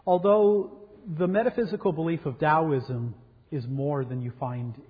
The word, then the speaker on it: Taoism: sometimes spelled with a D, but always pronounced with a D, Taoism, that Confucianism and Taoism are probably better described as moral philosophies of life.